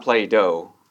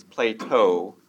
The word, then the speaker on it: Plato: Plato, if you've heard of Plato, not Plato, I said that wrong, it's Plato. Plato, the philosopher, he believed in leading others by showing kindness and humility.